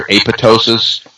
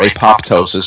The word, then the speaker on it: apoptosis: The word is either apoptosis or apoptosis. Some people pronounce the P, some people don't.